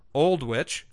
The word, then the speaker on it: Aldwych: Similarly, the station, Aldwych, pronounced Oldwych, was abandoned in 1994 and is used by film crews.